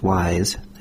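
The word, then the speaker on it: Ys: I think it's pronounced Wise, as in smart, but it's spelled Y-S.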